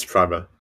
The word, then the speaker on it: Primer: I hadn't heard about, wait, okay, just to make sure, is it Premiere or Primer? How do you pronounce it? – It's Primer.